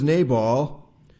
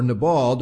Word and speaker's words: Nabal: His name was Nabal, or Nabal, depending on how you want to pronounce it, and his wife's name was Abigail.